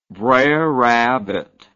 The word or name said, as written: brer rabbit